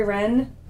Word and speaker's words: Rehn: To reach me, it's Lori Rehn, L-O-R-I. – How do you spell your last name? – R-E-H-N.